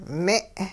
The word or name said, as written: me'